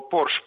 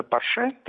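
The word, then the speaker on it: Porsche: шутку, когда известную, когда бомжи узнали, что Порш правильно произносится Порше, требовали, чтобы их называли бомже.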